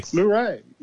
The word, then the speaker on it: Luray: Luray. – Luray.